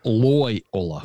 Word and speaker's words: Loyola: It's Loyola.